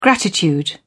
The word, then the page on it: gratitude: British English